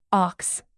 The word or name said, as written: ox